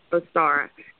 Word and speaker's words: Ostara: It's Ostara. I said that right, right? Yes, Ostara.